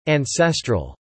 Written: ancestral

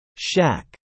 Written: shack

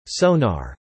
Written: sonar

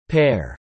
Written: pair

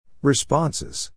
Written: responses

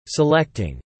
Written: selecting

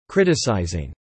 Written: criticising

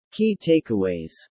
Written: Key Takeaways

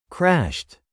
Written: crashed

